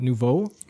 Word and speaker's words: nouveau: Nouveau.